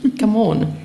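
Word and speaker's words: Gamon: Nein, aber meinen Namen spricht man anders aus, nämlich Gammon. Das muss ich bei jeder Gelegenheit dazu sagen. – Hätten wir auch. – Wenn ich es schon auf Band habe, dann erst recht. – Okay. – Das ist nämlich ein rätoromanischer Name.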